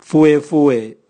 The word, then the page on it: vuevue: Raga